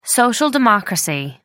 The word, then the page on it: social democracy: American English